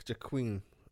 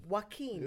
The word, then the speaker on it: Joaquin: So I'm looking forward to it because he looks like Jaqueen. – Joaquin.